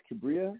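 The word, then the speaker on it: Kibria: My name is Imtiaz Kabria. That's spelled I, M as in Mary, T-I-A-Z, last name K-I-B as in boy, R-I-A.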